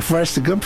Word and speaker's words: Forrest Gump: Forrest Gump, falei certo isso? Em inglês? – Falou.